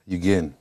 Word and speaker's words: Yugen: Yugen.